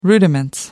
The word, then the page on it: rudiments: American English